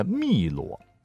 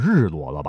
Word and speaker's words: 汨罗: 好，下面重点呢要讲一讲湖南的汨罗，你不会读成日罗了吧？